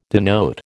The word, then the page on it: denote: American English